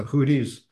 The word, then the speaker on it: Houthis: My son-in-law broached this topic with the Houthis trying to blow the oil. Did I pronounce that wrong? – Houthis, Oof. – Houthis. I pronounce the H.